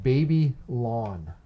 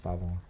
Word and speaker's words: Babylon: I think it's pronounced Baby Lawn. – Hmm. – No, it's pronounced Babylon.